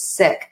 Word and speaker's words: Sikh: And so if you and I had talked 10 years ago, I would have known that the correct pronunciation is Sikh,